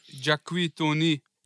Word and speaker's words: Jacques Tony: Produziert ist das Ganze von, wie wird es ausgesprochen, Bruder? Jack, Jacques. Jack, Tony. Jacques, Jacques Tony. Jacques Tony und BK selber.